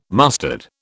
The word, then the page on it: mustard: British English